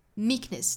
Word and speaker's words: meekness: meekness, 在读到 k 的发音的时候声音是非常轻弱的 meekness, meekness, weakness, weakness, 后面的这个 ness 里面对应的元音呢可以是短音的 e, 也就是 ness, 也可以是一个刷 sound, a ness, 也是可以的哈。